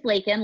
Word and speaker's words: Lakin: So my name is Lakin, like bacon. I often get asked how to pronounce my name, and I am originally from Texas, so we have that in common.